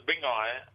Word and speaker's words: Bingai: Close, brother. Bingai, we say. And Bingai is like, it's a bit like Bunji, you know.